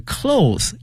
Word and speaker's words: cloth: 从原来的这个 clothes 衣服那变成了 cloth, C-L-O-T-H，把 es 去掉，同时呢发音从原来的哦变成了凹音啊，希望同学们一定要注意了。